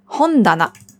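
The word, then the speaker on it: Hondana (本棚): Hon ist das Buch, Regal heißt Tana. Aber nur wenn es alleine steht, dann spricht man es Ta aus. Steht es zusammen in Kombination mit dem Hon, dann ändert sich die Aussprache auf Da. Hondana.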